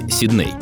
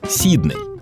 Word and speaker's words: Сидней: В принципе, традиции британской фонетики предполагают в таких случаях ударение на первый слог — Сидней. А великий и могучий на это хотел плевать, из барского плеча подарил городу аж на два ударения. Куда хошь, туда и ставь. Вот серьезно, словари именно так и говорят.